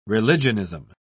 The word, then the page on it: religionism: British English